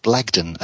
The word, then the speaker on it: Blagden: So remember the name, John Blagden, and that's spelt with an E, so it's B-L-A-G-D-E-N.